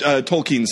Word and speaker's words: Tolkien: It's Tolkien. – Toucan? – It's Tolkien, Sam. – Tolkien. Okay. Toucan. Tolkien, Sam. Good.